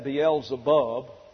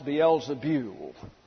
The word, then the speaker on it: Beelzebul: And you may have a King James Version that has that Beelzebub, but in the Greek it's Beelzebul, it's B-E-U-L, and the diphthong E-U in Greek gets a U sound, a Y-U kind of pronunciation. So I don't know why they left the E out in the New American Standard, but it's pronounced Beelzebul.